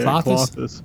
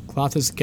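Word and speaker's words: Klothys: So we got the other god card, which is, I hope this I'm pronouncing this right, Clothis? – That's how I say it. Clothis. Yeah. – Clothis, god of destiny.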